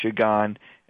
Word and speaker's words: Qigong: And again, Qigong is spelled Q-I, but people say it as Qigong, which is Q-I and then G-O-N-G, and what that is.